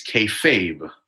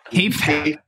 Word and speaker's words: kayfabe: It's kayfabe. – Kayfabe. K-Fab. I thought the E was silent. I'm over here with a silent E.